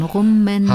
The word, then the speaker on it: Rummenigge: Rummenigge, Rummenage? – Spielte er dann. das ist auch falsch ausgesprochen. Rummenigge.